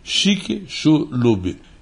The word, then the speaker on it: Chicxulub: Pronuncia-se assim, Chicxulub e